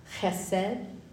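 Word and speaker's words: Chesed (חֶסֶד): Diane Barstow has a t-shirt with the Hebrew word chesed. You have to say it like you're gonna. Chesed.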